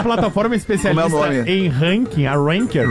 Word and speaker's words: Ranker: Como é que fala, Alexandre? – O Ranker.